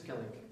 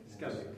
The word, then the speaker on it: Skellig: Hoe is dat goed uitspreken? – Skellig. – Skellig. Yes. Ja.